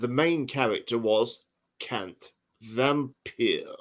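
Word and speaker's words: Wampir: The W's are pronounced with a V. So it's not vampire, it's vampire. Oh, Bram loved that.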